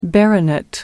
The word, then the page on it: baronet: American English